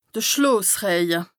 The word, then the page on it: de Schloosrëje: Bas-Rhin; Strasbourg